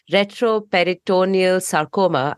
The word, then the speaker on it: retroperitoneal sarcoma: They used it to identify retroperitoneal sarcoma.